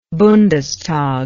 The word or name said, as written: bundestag